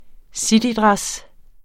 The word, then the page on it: citydress: ˈsidiˌdʁas; -ˌdʁεs